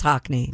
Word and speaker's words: Hackney: H-A-C-K-N-E-Y. – Oh, like the painter? – Beats me. – That would be Hackney. – Not Hackney. – Maybe it is Hackney, but it's spelled it's H-A. Oh, okay. It's Hackney, isn't it? – Yeah.